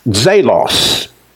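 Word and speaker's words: zelos (ζῆλος): Greek word for zeal is pronounced zelos. Zelos.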